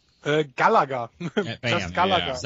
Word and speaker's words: Galaga: The way I've always squared it away in my head is that the Aussies pronounce it Galaga in honor of Alf Stewart's catchphrase, "You great Galah," as in the rose-breasted cockatoo. – No idea. – And the rest of the world pronounces it correctly.